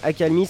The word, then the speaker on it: Akalmy: Akalmi, ça s'écrit A-K-A-L-M-Y.